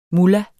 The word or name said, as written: mullah